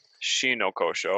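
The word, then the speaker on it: Shinōkōshō: They abolished uh, what was the caste system, which was called shono kosho, and I actually have everything written here. Uh, Shino Koshō. I already mispronounced it.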